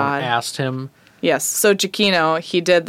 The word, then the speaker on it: Giacchino: I'm going to butcher his oh. last name because it's so Italian. – It's Giacchino. – Giacchino.